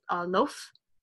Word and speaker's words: Loph: The story is set among the a race of peaceful and apparently immortal beings called, I'm making sure I pronounce this, Loaf, or L-O-P-H.